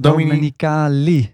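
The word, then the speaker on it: Domenicali: Stefano Dominicale. Zeg ik dat goed? Dom Dominicali. – Dominicali. – Dominicali. Dominicali. Ja.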